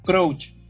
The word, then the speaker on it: Crouch: Crouch, dije, sí. ¿Cómo se dice? – No, yo le habría dicho Crouch, como el futbolista Peter Crouch, ¿se acuerdan?